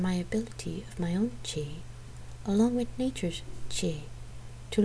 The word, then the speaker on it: qi: Qi, which you pronounce qi, is often translated as vital force, vital energy, or life force.